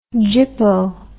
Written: jippo

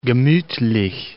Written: gemutlich

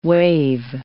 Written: wave

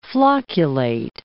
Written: flocculate

